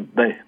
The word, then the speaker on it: Bex: Alors on prononce Baix, bon.